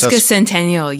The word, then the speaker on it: sesquicentennial: Is it sesquicentennial? – It's sesquicentennial, yes.